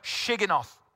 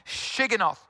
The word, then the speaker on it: Shigionoth: And Habakkuk uses, or it says in Habakkuk 3, and there's this word, Shigionoth. Yep, I actually said that right. Shigionoth.